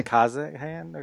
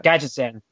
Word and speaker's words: Gadgetzan: It's a – One Night at Kazahan or Kazahan? – Gadgetzan.